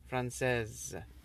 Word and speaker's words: français: Français is written with an S at the end. – I know that. – And you do not pronounce it.